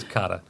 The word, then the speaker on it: kata: The term is kata.